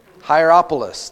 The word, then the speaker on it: Hierapolis: Hierapolis, there you go.